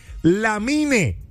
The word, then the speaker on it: Lamine: El nombre es Lamine. Lamine termina en EN. – Sí, pero Lamine. Se pronuncia Lamin. Termina en IN, que es como tú dices.